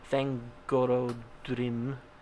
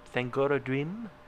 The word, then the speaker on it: Thangorodrim: Thangorodrim. – Yeah, you said it right, but you just said it really slow. – I did. I stopped myself up on it. Thangorodrim and the Iron Mountains, consuming the plains of Ard Galen and turning them into a desert called Angfaugrith.